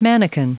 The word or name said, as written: manikin